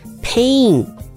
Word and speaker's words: Pain: Pain is spelled P-A-I-N，请注意它是 A 长母音的音、嘴巴要往旁边裂开。